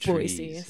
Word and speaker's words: Boise: You can usually tell people who aren't from here because they'll say Boise, which Boise. I know it feels wrong saying it like that. So it is city pronounced Boise.